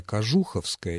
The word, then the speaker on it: Кожуховская: Или Кожуховская, мне казалось, или Кожуховская, а вот нет, оказывается, Кожуховская, именно так называется эта станция.